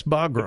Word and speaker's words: Bagram: was it Bagram you said? Is that, am I pronouncing that right? – Yes, Bagram. Okay. Correct.